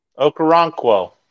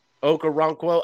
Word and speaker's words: Okoronkwo: And you still got, Oboe and Rockro. Did I still say it right? – Okoronkwo. – Okoronkwo.